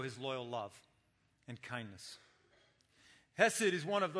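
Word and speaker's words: chesed (חסד): And it's the Hebrew word chesed. Okay, you say it like there's something caught in your throat, chesed.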